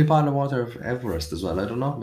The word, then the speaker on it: Everest: Mount Everest is pronounced Everest, not Everest, as it is named after George Everest.